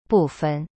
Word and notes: 部分: bu fen

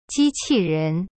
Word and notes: 机器人: ji qi ren

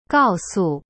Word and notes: 告诉: gao su

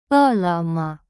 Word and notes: 饿了么: e le me

